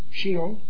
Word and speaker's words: Sheol: Sheol. – Sheol. To await resurrection to judgment. The spirits of the unsaved dead in the Old Testament were thus separated from God and in agony and conscious torment. Sheol is a temporary holding place for both the body and spirit of the unsaved in the Old Testament.